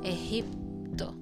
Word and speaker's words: Egipto: With the G in the middle and it's pronounced like J. Egipto.